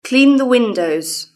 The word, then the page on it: clean the windows: British English